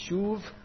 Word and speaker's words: shub: Shub is the Hebrew word. You could even pronounce it as a V. There's different schools of thought on how that consonant's pronounced. It's shuv or shub.